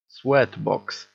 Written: sweat box

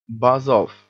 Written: buzz off

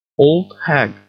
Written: old hag

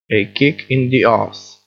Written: a kick in the arse

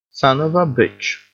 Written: son of a bitch

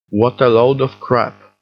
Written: what a load of crap

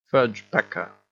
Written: fudge-packer

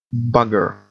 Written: bugger

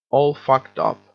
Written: all fucked up

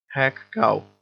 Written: hag-cow

